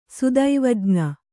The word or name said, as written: ಸುದೈವಜ್ಞ